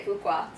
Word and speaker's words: Fuqua: Is that how you say his name? – I would say Fuqua. – Fuqua.